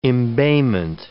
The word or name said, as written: embayment